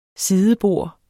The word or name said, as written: sidebord